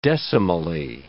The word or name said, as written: decimally